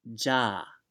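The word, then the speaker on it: ja (じゃ): Well, that well in Japanese is pronounced ja.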